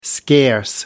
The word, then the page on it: scarce: American English